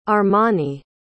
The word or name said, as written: armoni